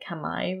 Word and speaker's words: Khmer: But because of the history of Cambodia, and there was the civil war, and then there was the Khmer Rouge, or actually they pronounce it Khmer Rouge, even though in the West we say Khmer.